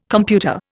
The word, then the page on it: computer: Indian